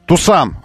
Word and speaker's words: Tucson: Tucson. Я слышал такое произношение. Tucson. Tucson называется. Tucson.